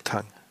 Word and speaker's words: tongue: Tongue in Cheek? Tong, ist es nicht Tongue in Cheek? Tang. Tang, ach so, ja. Tang, ach so, Tang.